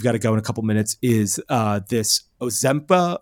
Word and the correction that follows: Ozempic: Ozempic, yeah.